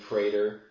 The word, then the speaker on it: Praetor: Is it Praetor? – Shh, wasn't that good. – I'm going to see if I'm right. – You are right.